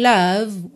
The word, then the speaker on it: love: Percebe que eu termino só na letra V. Love. E o O não tem um som de O, não é love. Tá? E não é love, porque esse F final não é pronunciado, tá pintadinho de cinza aí, tá?